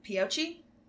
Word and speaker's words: Pioche: No, in Peochee. – Yeah, okay. – Am I saying that right? – I think so. – Pioche.